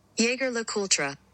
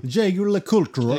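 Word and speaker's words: Jaeger-LeCoultre: Och om man har lite amerikanskt uttal mm. så låter det så här. Jäger LaCoultra. Ja, oh, herregud. Pinsamt. Eller hur? Jaeger LeCoultre. Exakt.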